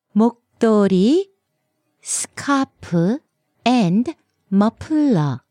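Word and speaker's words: 머플러: 네, but a lot of times Korean people will also call it a muffler. – Right, what's the Korean pronunciation for that? – It's muffler. Muffler.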